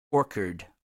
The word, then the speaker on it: Orchard: Is singer, songwriter, musician Merrill Lee, who has also performed and released records under the moniker Merrill Lee and the Poison Orchid. Orchard, Orchard, Orchard, Orchard, O R C H A R D, if I'm not pronouncing it right.